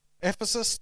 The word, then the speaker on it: Ephesus: Ephesus? Ephesus? Okay. Ephesus, to put on the whole armor of God, because you are under attack.